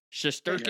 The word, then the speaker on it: Shesterkin: Shesterkin.